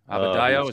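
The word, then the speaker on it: Adebayo: Abadayo, is that how you say it? – Yeah, spectacular.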